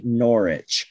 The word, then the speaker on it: Norwich: I think Norwich. I think you like don't pronounce the W if okay. I'm correct. Norwich City.